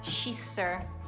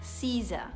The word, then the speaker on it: scissors: L'ho sempre pronunciata shisser e me ne vergogno veramente tanto. Quindi impariamo la pronuncia corretta di forbice, forbici in italiano. Si pronuncia Caesar, Caesar.